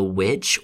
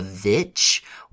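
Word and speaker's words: VVitch: the witch or the vitch, whatever you, you know, use to pronounce that movie, uh, know that Robert Eggers is one of those filmmakers that we, we're interested in, but we're also a little scared of.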